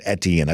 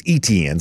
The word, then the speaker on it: Etienne: and I guess I've been mispronouncing it as Etienne. I've been told it's Etienne. So let me make sure I give the long E sound there at the beginning there on that.